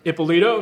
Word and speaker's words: Ippolito: We're here with Nick Saltzman and John Ippolito. Did I say that correctly? – You said it perfectly. I'm excited to be here. So, nice. And I'm excited you pronounced my name the right way.